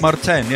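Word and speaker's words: Martijn: Is it Martin or is it pronounced differently? Martin Noisia. – It's Martin, right? – Martin, yeah. – Yeah, in Dutch it's Martin. – With a kind of J, isn't it? – Yeah, exactly.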